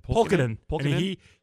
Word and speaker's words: Pulkkinen: Ryan Reeves' interference against Timu, how do you say his name? – Pulkkinen.